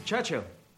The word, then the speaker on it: Churchill: Churchill. That's how you say it. All together like that.